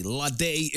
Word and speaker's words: Lahde: L-A-H-D-E. Lade is the pronunciation.